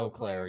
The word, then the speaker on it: Eau Claire: Its current owners live in, I'm going to probably butcher this, Eau Claire? Eau Claire? – Eau Claire, yeah.